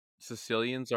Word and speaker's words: caecilians: But also, I will say for salamanders, this is odd because when we consider other amphibians, so uh, like frogs, uh, caecilians, which is a type of legless amphibian, they have the biggest diversity in tropical regions. Wait, Caecilians are caecilians are legless amphibians.